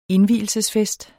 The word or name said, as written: indvielsesfest